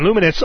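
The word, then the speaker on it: Lumines: They've gotten to run the game Luminous. That's how I hear people pronouncing it, so we butchered it a few weeks ago. They can run Luminous off of the memory stick.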